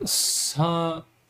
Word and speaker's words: Southington: And then Joseph $2 said, I'm from Connecticut. It's pronounced Southington. – Oh, yeah. Southington.